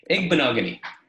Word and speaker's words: Igbinoghene: Igbinoghene. – Thank you for sounding it out for me. Igbinoghene.